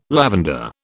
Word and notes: lavender: British English

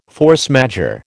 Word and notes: force majeure: American English

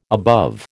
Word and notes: above: American English

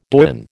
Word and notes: doyen: American English

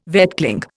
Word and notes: vetchling: Indian

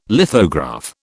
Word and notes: lithograph: British English

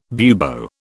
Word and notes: bubo: British English

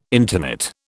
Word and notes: intonate: British English

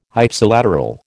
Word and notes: Ipsilateral: American English

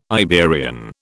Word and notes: Iberian: British English

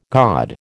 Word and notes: god: American English

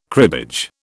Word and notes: cribbage: British English

